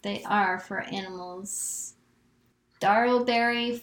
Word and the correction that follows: Darrowby: Farm Sanctuary. Am I pronouncing that correctly? I'm sorry. – Darrowby Farm. – Darrowby.